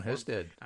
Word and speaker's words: Husted: Two nights ago, it was Ohio, and he was talking to I'm reliably John Husted. – That is John. Apparently, the local pronunciation is Husted. – Houston. – Yeah, right. So, yeah, like the like Houston, but without the O. But then every once in a while, you run into a H-O-U that's pronounced Huston anyway.